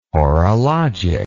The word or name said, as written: horologic